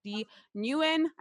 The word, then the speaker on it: Dee Nguyen: And obviously, that's at D Nguyen.